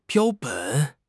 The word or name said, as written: 标本